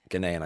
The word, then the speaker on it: Ghanaian: Ghanaian, I said it right, didn't I? – Yeah.